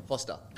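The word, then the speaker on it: Forster: Also, the stores in Spotlight, Great Northern Hotel Chatswood, Federal Hotel Mudgee, Royal Hotel Orange, BYO Sellers Foster, Faster. – Is that Foster? – Foster. – F-O-R. Is that how it's spelled? – Yeah, yeah. It's kind of weird spelling.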